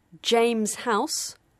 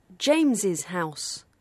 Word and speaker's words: James's: You would choose pronunciation accordingly, either is or isses. – James House or James's House.